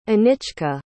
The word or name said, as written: anichka